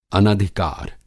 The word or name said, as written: अनधिकार